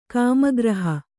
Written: ಕಾಮಗ್ರಹ

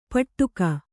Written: ಪಟ್ಟುಕ

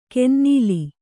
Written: ಕಿನ್ನೆಲ

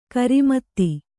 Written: ಖರಾಮತಿ

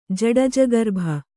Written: ಜಡಜಗರ್ಭ

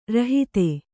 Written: ರೋಹಿತ

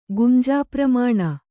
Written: ಗುಂಜಾಪ್ರಮಾಣ

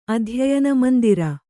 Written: ಅಧ್ಯಯನಮಂದಿರ

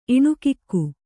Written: ಇಣುಕಿಕ್ಕು

